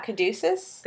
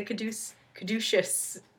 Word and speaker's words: caduceus: Oh Cadu- yeah, Caduceus. The Caduce Caduceus it's spelled C A D U C E U S.